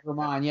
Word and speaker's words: Germán: Germán.